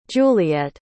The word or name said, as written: juliette